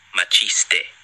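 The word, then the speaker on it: Maciste: Here, wait, speaking of, I've just received a pronunciation guide. – Oh. – Cabiria and Machiste.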